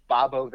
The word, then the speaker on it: Bobo: And it's spelled B-O-B-O with a capital O. So to me, that tells me that that's now the third thing I've seen that says Bob-O. – Yeah. – You seem to think it's Bob-O.